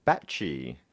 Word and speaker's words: Bachy: And I'm going to go with this pronunciation, Alex Backey. – Backey. – All right, I'm going to have a stab. Mm-hmm. I'm going to say Alex Batchy. – B-A-C-H-Y.